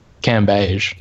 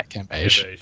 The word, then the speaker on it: Cambage: So also, I believe that her she's insisted that her surname is to pronounce Cambridge as well. – Cambage. – Cambage, is it? Cambage. – Yeah, Cambage.